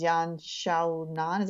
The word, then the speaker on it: Yan Xiaonan: Joanna versus Yan Xiaonan. Is that how you pronounce that? – Close enough, yeah.